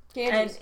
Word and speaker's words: Ganges: Ganges.